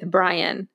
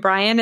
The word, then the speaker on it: Bryann: You can also find me and my life coaching business at Rebuilding with Bryann. And Bryann is spelled B-R-Y-A-N-N.